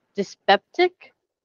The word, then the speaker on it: dyspeptic: She opened her mouth and eyes pretty wide and let her jaw drop sideways and managed to look so like a, and I'm going to pronounce this word incorrectly, dyspeptic? – Yeah, no, you got it.